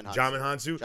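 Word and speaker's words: Djimon Hounsou: Dizmon Hansu? Is that how you say it? – Jaimin Hansu.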